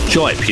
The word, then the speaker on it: Jipe: I was calling him DJ Ike for so long, but yes, Jipe. – Yeah, well, that's how I've been saying it, but it's Jipe, yeah?